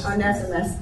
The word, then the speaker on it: Onesimus: Onesimus.